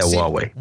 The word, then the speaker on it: Huawei: Yeah, Huawei.